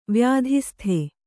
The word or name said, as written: ವ್ಯಾಧಿಸ್ಥ